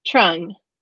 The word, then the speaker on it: Truong: Truong.